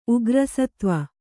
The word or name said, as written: ಉಗ್ರಸತ್ವ